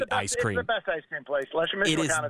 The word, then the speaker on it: La Michoacana: but La Michoacana on summer. – Oh, yeah, La Michoacana. That's La Michoacana.